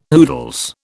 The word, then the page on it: oodles: British English